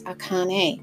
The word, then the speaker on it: Akan: the word is often pronounced Akane, and it is said to mean foremost, genuine, from Khan first.